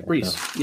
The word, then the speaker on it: Breece: Breece, right? – Yeah, right? Okay. Breece.